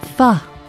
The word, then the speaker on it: pho: pho. Yeah, that's right, that's how you say it. Pho.